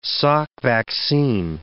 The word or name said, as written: Salk vaccine